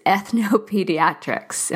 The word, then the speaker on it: ethnopediatrics: So let's jump in a little bit to, what is ethnopediatrics? Am I pronouncing that right? – Yes, yes, you are.